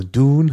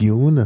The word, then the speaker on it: Dune: Also Dune heißt es und nicht Dune, das ist so eine bisschen britische Aussprache.